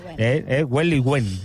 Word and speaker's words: Huelihue: Realmente, un ballet extraordinario que viene de Chile, Huelihue, lo he dicho bien, ¿no? – Huelihue, exactamente.